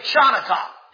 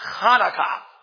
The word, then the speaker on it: Hanukkah: I even hear some Christians say Chanukkah because of the CH, depending upon how you spell Hanukkah, but it's Hanukkah. And now we have an H up there.